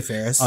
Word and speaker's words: Faris: I say Faris.